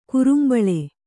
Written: ಕುರುಂಬಳೆ

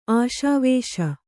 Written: ಆಶಾವೇಶ

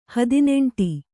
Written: ಹದಿನೆಂಟು

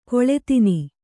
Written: ಖಳಧಾನ

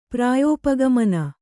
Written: ಪ್ರಾಯೋಪಗಮನ

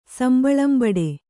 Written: ಸಂಬಳಂಬಡೆ

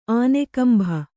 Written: ಆನೆಗಂಭ

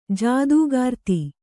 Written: ಜೊತೆಗಾರ್ತಿ

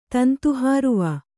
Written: ತಂತುಹಾರುವ

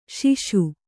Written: ಸಾಸ

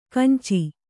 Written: ಕಾಂಚಿ

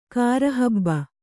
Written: ಕಾರಹಬ್ಬ

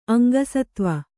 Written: ಅಂಗಸತ್ವ

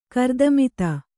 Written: ಗಾರುತ್ಮತ

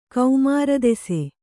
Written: ಕೌಮಾರದೆಸೆ

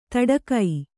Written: ತಡಕೈ

